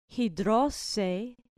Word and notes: hidrosein: grec

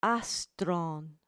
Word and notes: astron: grec

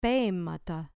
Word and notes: pemata: grec

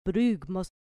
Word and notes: brugmos: grec

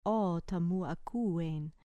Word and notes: ota mu akuein: grec

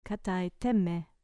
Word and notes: katai te me: grec

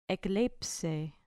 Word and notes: ekleipsei: grec

